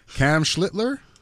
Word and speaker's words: Schlittler: Cam Schlittler? – At least you said it right.